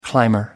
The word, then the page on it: climber: American English